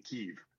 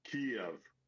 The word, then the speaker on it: Kyiv: It is, in my mind, as someone who has lived in Ukraine and spent so much time in the Slavic-speaking world, it is not as easy as simply saying Kyiv. It's not a single-syllable city. It is Kyiv.